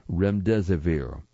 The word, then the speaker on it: remdesivir: I will go back and spell remdesivir. It is spelled R-E-M-D-E-S-I-V-I-R, remdesivir, an antiviral drug that had been developed for Ebola.